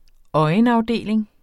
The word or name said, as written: øjenafdeling